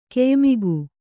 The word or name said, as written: ಕೆಯ್ಮುಗಿ